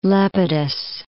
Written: Lepidus

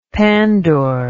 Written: pandour

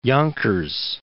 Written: Yonkers

